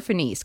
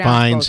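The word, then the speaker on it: Fiennes: Is it Fines or Finis? Because I've heard it pronounced Fines. – Both ways. Fines? – Yeah.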